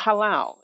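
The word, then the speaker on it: halal (הלל): It's called halal. It's pronounced H-A-W-L-A-L, and it's written H-A-L-A-L.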